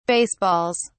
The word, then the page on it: baseballs: British English